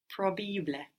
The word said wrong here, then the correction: probabile: Take it slowly, syllable by syllable. – Probabile. – Probabile. – Probabile. – So it's just like the English. If you think of the English probable, and then you make each of those vowels more Italian sounding, so probabil. – And then you pronounce the E that we don't pronounce in English.